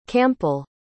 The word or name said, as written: campal